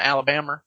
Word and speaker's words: Alabama: I don't want to distract you too much, but you pronounce Alabama, Alabama. Is that the official state pronunciation of the state? – Yes.